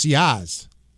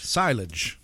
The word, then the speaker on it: sillage: It's S-E-E, little, small, S, small E, dash, capital A, capital H, capital Z, capital H is the phonetic pronunciation. But the word is spelled S-I-L-L-A-G-E. – S-I-L-L-A-G-E.